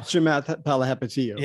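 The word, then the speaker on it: Chamath Palihapitiya: How do you say it? Chalapa Tapa? – Yeah, I don't. Chamath Palihapitiyo.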